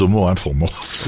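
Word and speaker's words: Homo informans: Comment je dois le prononcer, celui-ci? – Moi, je l'ai fabriqué informance. Alors, à la française, homo informance.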